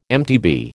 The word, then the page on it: MTB: American English